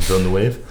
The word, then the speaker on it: Villeneuve: Directed by Denis Villeneuve. Shot by... – I don't know. I'm not French. Bradford I don't know Young. How to pronounce his last name. Villeneuve.